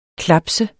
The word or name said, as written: klaps